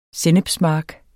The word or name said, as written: sennepsmark